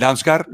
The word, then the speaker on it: Damsgaard: Gol de Mikel Damsgaard. ¿Lo he dicho bien, carritos, sí, sí.